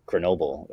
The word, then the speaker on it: Grenoble: In Grenoble. – Tell us the good stories. – Where Soy's from this like small town kind of in the Alps called Grenoble, or Grenoble as they pronounce it.